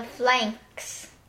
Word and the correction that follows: phalanx: Pronounced phalanx.